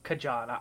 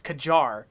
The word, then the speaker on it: Kajar: It's not Kajan. I'm sorry, internet. It's Kajar, the magic city.